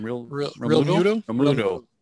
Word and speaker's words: Romualdo: Is that how you pronounce the name? – Romualdo. Yeah.